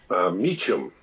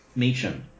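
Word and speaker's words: Meachum: Meacham. – Meacham.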